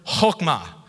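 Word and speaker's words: chokmah (חכמה): In Hebrew, it is the word chokmah.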